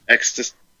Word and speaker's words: existential: Existential? Say it again. – Exist. Say exist. – Exist. – Potential. – Potential. – Existential.